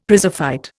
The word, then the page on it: prizefight: Indian